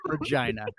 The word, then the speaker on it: Regina: Regina. – That is how you pronounce it for real.